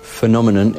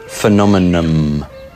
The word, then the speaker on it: phenomenon: Phenomenon is a four-syllable word with a stress on the second syllable. PH is pronounced F, and the final N can be pronounced M. Phenomenon. Phenomenon. That's how I say it. Now, you can also pronounce it as phenomenon. The difference is whether the lips are closed at the end. I find it easier and smoother to just say phenomenon.